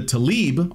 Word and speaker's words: Tlaib: As cited by the Washington Examiner, Tlaib continued, I hope I'm not butchering her name because it's spelled funny, it's like T-L-A-I-B, Tlaib, continued, "More came later, uglier, more violent.